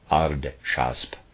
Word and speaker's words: Ardshasb: Ardshasp is spelled A-R-D-S-H-A-S-B, Ardshasp.